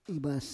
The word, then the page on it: ivas: Roon